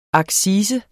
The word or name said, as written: accise